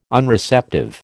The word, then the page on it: Unreceptive: American English